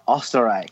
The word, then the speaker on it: Österreich: Bomb listener feedback brought to you this week by Ich liebe Österreich. – I'm impressed you said it right.